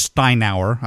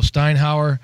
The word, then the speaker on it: Steinauer: So I also got a clip of him talking about Coach Steinauer, I believe is how we pronounce it. But here's what Tedford had to say about him. – Our defensive coordinator, Orlando Steinauer, came from Canada in the CFL.